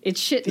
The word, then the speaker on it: Schitt: And of course they spell it S-C-H-I-T-T. So it's not spelled the same as the other word, the way it sounds. Like it's shit, the not S shit.